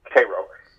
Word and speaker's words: Cairo: which is spelled like Cairo, but it's Cairo.